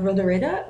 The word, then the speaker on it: Rodoreda: Um, oh, and I did, P.S., look it up, and my pronunciation of Rodoreda was as close as I am going to get because I don't know how to roll my R's.